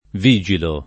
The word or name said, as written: vigilare